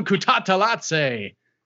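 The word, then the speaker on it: Kutateladze: I was telling Casey that if I pronounce Kutatalatsi, I have to pronounce it like a ring announcer because that's how I heard it and got the pronunciation. So you can't just say it normally.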